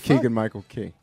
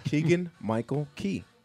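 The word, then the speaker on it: Keegan-Michael Key: It's Keegan Michael Key. – Keegan Michael Keegan. – Say it right, please. – Keegan Michael Cagle. – Cagle. – It's very simple. Keegan Michael Key.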